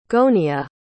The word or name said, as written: gonia